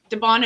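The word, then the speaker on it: DeBaun: If anybody, am I mispronouncing DeBon? – We're going to go with it. I think it sounds, that looks right to me. – DeBon it is.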